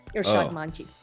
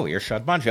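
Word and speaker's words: Irshad Manji: Well, I mispronounced your name up front. Irshadmanji. – Oh, right. You know what? I anglicize it because, you know, it's now a habit, but you pronounce it beautifully from the get-go. Irshadmanji.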